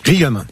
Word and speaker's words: Riom: Riom.